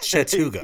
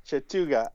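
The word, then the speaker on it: Chattooga: So you said Chattahoochee County. That is not... It looks like Chattanooga, right? But it's just Chattuga. – Yep. Chattuga. I saw an extra H there. – You threw an extra syllable in.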